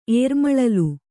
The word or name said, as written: ಏರ್ಮಳಲು